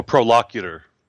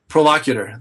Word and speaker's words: prolocutor: Even, you know, William Twist, as we know, or some people might not know, was like the first, how do you pronounce it? – Oh, prolocutor. – Prolocutor. Thank you.